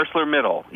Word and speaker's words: ArcelorMittal: Explain to me the significance of ArcelorMittal, is that how you say it? – Yeah, ArcelorMittal.